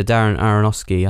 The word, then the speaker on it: Darren Aronofsky: And I think a lot of the credit has to also go to the director, Darren Aronofsky.